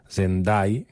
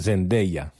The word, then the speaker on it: Zendaya: Vaig veure un vídeo un dia que ella ho explicava, perquè es veu que la resta del món li diuen Zendai, però Dai és com morir, no? Dai. I s'escriu Day com dia, i és Zendaya.